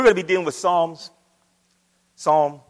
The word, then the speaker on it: Psalms: we were reading a little bit about Psalms. And I said, do you know the book of Psalms? And she looked at me and she said, yes, the P is silent.